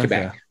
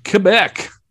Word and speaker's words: Quebec: Yeah, like almost like a K, like okay. Quebec. – I'm going to try to say that. – Yeah. – Quebec.